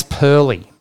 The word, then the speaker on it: Purley: Now, actually, I think it's Purley. Purley, it's pronounced. David Purley.